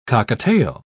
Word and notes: cockatiel: British English

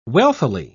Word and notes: wealthily: British English

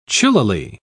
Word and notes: chillily: British English

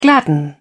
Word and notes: gladden: British English

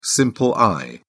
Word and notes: simple eye: British English

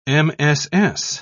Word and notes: mss: British English

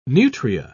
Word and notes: nutria: British English

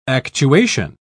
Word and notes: actuation: British English

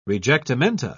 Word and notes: rejectamenta: British English

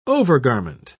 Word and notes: overgarment: British English

